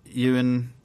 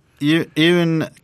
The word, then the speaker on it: Eoin: But the art by Ewan... How do you say E-O-I-N? I think it's Ewan. Ewan? – I don't even know what you're saying to me now. – The name, E-O-I-N.